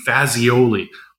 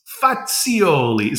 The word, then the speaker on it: Fazioli: I said, I promise you that everyone in Vancouver will know the name Fazioli. And he said, no, it's Fazioli. So he corrected my pronunciation.